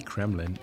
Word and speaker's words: Kreml: Now, something you might have not known is that Moscow Kremlin, the Kremlin, is actually just a Kremlin, one of many. The Russian word Kreml describes a citadel, and many historic Russian cities still have one. – Nice pronunciation of Kreml.